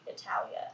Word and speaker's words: Italia: I know that Italy is not pronounced Italy in Italy. – Mm-hmm. – But I'm not going to say Italia out loud.